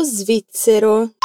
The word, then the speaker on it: svizzero: Hai fatto attenzione alla pronuncia di svizzero? Smettiamo la parola in due. Ripeti. Zero. Zero. Svi. Svi. Svizzero.